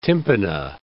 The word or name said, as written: tympana